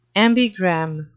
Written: ambigram

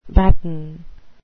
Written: batten

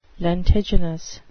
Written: lentiginous

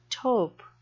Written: taupe

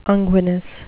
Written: unguinous

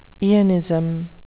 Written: eonism

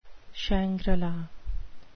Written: shangri-la

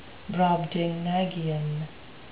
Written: brobdingnagian

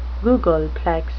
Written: googolplex

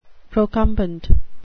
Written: procumbent

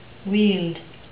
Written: weald

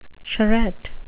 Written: charrette

